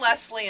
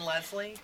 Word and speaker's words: Leslie: I'm Leslie and Leslie. Like people say it both ways.